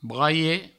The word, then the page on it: braiet: braie